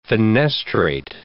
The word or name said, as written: fenestrate